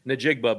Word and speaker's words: Njigba: You know, Jaxon Smith how do you say his last name? – Njigba, but you got big name players.